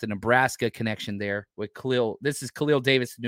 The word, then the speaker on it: Khalil: Let's talk about, it's pronounced Khalil.